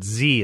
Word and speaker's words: xe: So, for example, XE slash XYR is commonly pronounced Z and Zir, as in, I asked Zir to come to the movies, and Z said yes.